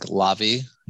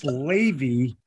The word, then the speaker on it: Lavie: For some reason, my parents decided to spell my name L-A-V-I-E. So like the restaurant, the JFK airport, L-A-V-I-E. So it's like Lavi. You know, people pronounce it Lavi, but actually it is pronounced Levy. Well, at least. Right. – So it's not Lavi like latte. It's Levy.